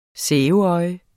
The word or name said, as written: sæbeøje